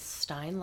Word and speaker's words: Stein: Oh, Steen is sometimes the pronunciation of Stein. I should ask Nikki Stein to come on. But it's Stein like Einstein, not Steen like Epstein.